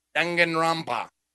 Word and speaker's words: Danganronpa: I've always said Danganronpa, but it's probably a little bit butchering the pronunciation. – It's fine. Trigger, Danganronpa. – I remember I could hear it in my head. Danganronpa. – Oh, is that how it's announced? – Like in the intro.